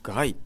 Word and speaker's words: 街: シニカルかつブラックな笑いで世間を煙に巻きますローパワーズがお送りする防災コント、果たして上演可能か可能なのかということでですね、脚本演出、チャーハンラモンさん、によるね、ローパワーズのこれ街って書いて、難しい方の街ですね、行人名の街って書いてガイと読む、ローパワーズのガイという、名前の、演劇、大変面白そうになりそうなので、面白くなりそうなので、ぜひ、行っていただけたらと思います。